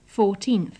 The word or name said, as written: fourteenth